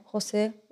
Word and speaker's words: José: J-O-S-E? Schon, oder? Ja. Okay. José ist richtig?